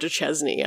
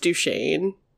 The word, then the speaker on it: Duchesne: I said Fort Duchesne. I don't know why I said that. It's pronounced Duchesne, which is like French.